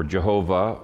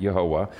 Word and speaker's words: Yehovah: Anytime you see Lord in all capital letters, it's the covenant name Yehovah, or Jehovah, or Yahweh. Different people pronounce it different ways. I pronounce it Yehovah.